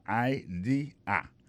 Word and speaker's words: idea: C'est I-D-E-A. Ça veut dire idée. That's a good idea. Alors, accent vient de tomber dans la deuxième syllabe là. One idea. Alors, moi, c'est idéal. Vous faites fait trois syllabes vraiment. I, D, A.